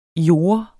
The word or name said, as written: jorde